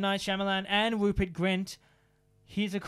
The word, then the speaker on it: Shyamalan: Now, M. Night Shyamalan, Shyamalan, that's how you pronounce it, was offered to direct the Harry Potter movie countless times.